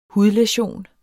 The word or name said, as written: hudlæsion